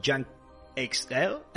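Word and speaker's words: Junkie XL: Y eh, también cuenta con una banda sonora eh, muy potente, compuesta en este caso por Yankee XL o Yankee Excel, como, como se pronuncia en inglés,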